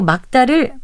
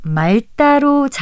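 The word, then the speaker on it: 맑다: 아, 그리고 막다를 말다로 잘못 발음하는 경우도 있는데요. 곁받침리 기억은 처럼 단독으로 쓰이거나 막다나 박다와 같이 자음 앞에 쓰일 때는 기억만을 발음해서 막다, 박다로 발음한다는 것 기억해 두시면 좋겠습니다.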